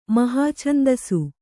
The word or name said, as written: ಮಹಾಛಂದಸು